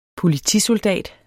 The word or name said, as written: POLITISOLDATER